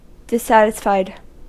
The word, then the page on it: unzufrieden: [ˈʔʊntsuˌfʁiːdn̩]; [ˈʔʊntsuˌfʁiːdən]